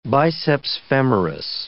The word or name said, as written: biceps femoris